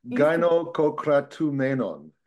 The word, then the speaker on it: gynokokratumenon: Ethnos gynokokratumenon. – Is that correct? – Yeah. Are we close there? – Yeah, you're good. Gynokokratumenon.